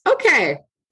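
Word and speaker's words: OKeh: Through his producing companies, OK, literally how it's spelled. – Stop it, really? – Yeah. – Wow. – Is it just fine? Well, it's OK. For our non-visual folks, it's O-K-E-H Records.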